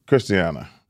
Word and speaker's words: Christiana: Why do we always say Christiana? – That's the same thing. – Not exactly. – Hey, listen. – Depends on what the mall's pronounced during that, because we got to check with that. – I pronounce it Christiana.